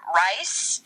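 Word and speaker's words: Reiss: The author of the book is Tom Rice. I think that's how you say it. It's R-E-I-S-S.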